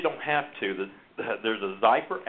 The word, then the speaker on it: Xypper: And it's called Zyper, and it's spelled X-Y-P-P-E-R, Zyper.